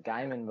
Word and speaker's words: Gaiman: Anyway, I have heard people pronounce it Gaiman before, but I don't think I've ever heard him actually like actually pronounce it that way. I think it is. – He says Gaiman when he talks about himself. – Yeah. – So, I think that's probably what we should say.